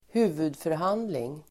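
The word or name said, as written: huvudförhandling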